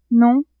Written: non

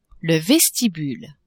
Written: vestibule

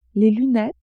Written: lunettes

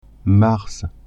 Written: mars

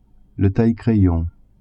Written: taille-crayon

